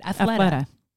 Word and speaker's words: Athleta: But the one that I've fallen in love with, and you're the one who's turned me on to this, I'm going to say their name wrong. Athleta. – Oh yeah. – That's it. Athleta.